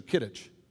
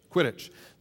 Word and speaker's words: Quidditch: And the Gryffindors, the good guys, have is it Quidditch or Kidditch? Quidditch.